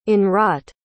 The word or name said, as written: in-wrought